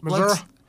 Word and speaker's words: Missouri: And I don't think he's from there because he didn't say it right. – So. Well, Missouri.